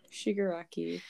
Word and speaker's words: Shigaraki: No, you have it right. – Okay, Shigaraki. Shigaraki.